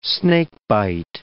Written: snakebite

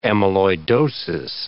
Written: amyloidosis

